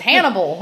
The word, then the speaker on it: Hannibal: His yeah. name rhymes with cannibal. It's Hannibal.